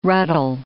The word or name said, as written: ruddle